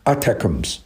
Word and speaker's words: ATACMS: 那么但是乌克兰这几天呢就不得了了啊，应该说乌克兰呢是用了这个 6枚美国的 ATACMS，我们一般把它读成 ATACMS，这个 6枚这是美国非常好的导弹，还用了 6枚英国制造的风暴阴影的巡航导弹，一共呢发射了 12枚，这 12枚导弹非常厉害，并且都打中目标了。